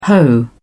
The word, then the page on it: hoe: British English